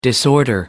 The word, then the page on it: disorder: American English